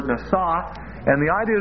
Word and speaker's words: nasa: And that's spelled like this, N, long A, S, long A, nasa.